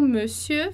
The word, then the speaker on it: monsieur: Então, o monsieur vai ser ali, me, né, fechado, monsieur. Eu vou até tirar esse e aqui para você perceber a diferença.